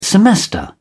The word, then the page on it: semester: British English